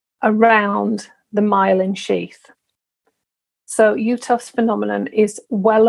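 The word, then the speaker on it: Uhthoff: The next one that I want to make sure you're aware of is something that's called UTOFS phenomenon. So UTOFS is spelled UHTHOFFS, UTOFS phenomenon. And I'm putting this in with the visual issues of MS, just to make you aware.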